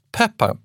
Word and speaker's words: Pepin: När du nämnde den här Pippin den andra. Precis. Jag har ju alltid uttalat det namnet Pippin. – Pippin, precis. Pepin, P-E-P-I-N, så uttalas det på franska.